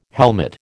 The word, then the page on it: helmet: American English